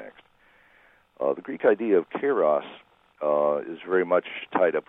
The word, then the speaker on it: Kairos: I'm talking about Kairos, is that pronounced correctly? And Chronos? – Yeah, Kairos and Chronos.